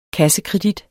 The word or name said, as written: KREDIT